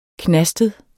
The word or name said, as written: knastet